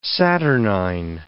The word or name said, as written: saturnine